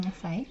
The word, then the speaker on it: acai: Mm-hmm, acai.